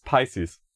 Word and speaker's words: Pisces: Offizie offizielle Aussprache ist Pisces.